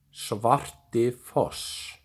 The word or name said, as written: Svartifoss